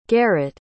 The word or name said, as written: garet